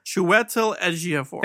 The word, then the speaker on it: Chiwetel Ejiofor: Copley's Chiwetel Ejiofor.